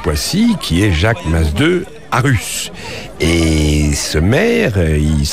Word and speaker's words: Masdeu: Oui, monsieur Jacques Masdeu Arus. – Oui, voilà, vous prononcez en catalan là, Masdeu.